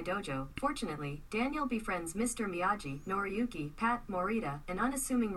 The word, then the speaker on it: Miyagi: It's Macchio and it's Miyagi. So anybody that's watched the movie several times, you know that you know that's the way that those are pronounced.